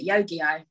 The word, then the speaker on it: Yu-Gi-Oh!: And I know that you're a fan of Yu-Gi-Oh! Is that how you say it? – Yu-Gi-Oh!